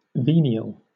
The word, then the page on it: venial: British English